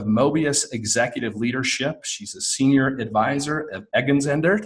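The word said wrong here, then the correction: Egon Zehnder: Did I say that right? – Egon Zender, which is an executive recruiting group and doing a phenomenal job there.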